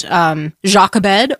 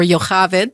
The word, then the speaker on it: Jochebed: But the reason I thought of it is because we were talking about mothers, and Matt's Mother's Day sermon was about Jochebed or Jochaved, depending on how you say it, but Moses' mother.